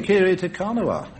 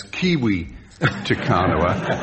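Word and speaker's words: Kiri Te Kanawa: Dame Kiri Takanawa. – Is it? – Yes. – I heard somebody pronounce that name as Kiwi Takanawa,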